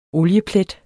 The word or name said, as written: OLIEPLET